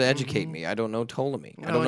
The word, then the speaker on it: Ptolemy: He was Ptolemy. – And the funny thing about Ptolemy is he's the only one that would pronounce the P.